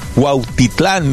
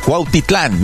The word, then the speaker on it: Cuautitlán: Ya lo dije bien, no se va a burlar de mí. No lo dije mal. Cuautitlán, México.